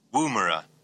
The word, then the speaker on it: Woomera: Woomera.